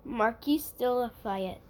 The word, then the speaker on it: Marquis de Lafayette: So, the first story, it first cracked back in 1824 during the visit of the Revolutionary War hero, Marquis de Lafayette. – Very nice on that French pronunciation there, yeah.